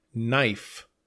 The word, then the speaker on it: knife: Next, we have knife, K-N-I-F-E. Be careful, the K is silent. So we say knife, not knife.